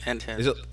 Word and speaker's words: Enthead: Like, Enthead.